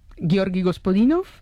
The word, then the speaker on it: Gospodinov: A ver, Georgi Gospodinov, ¿sí? – Zdravka Eftimova y Vera Mutavcheva.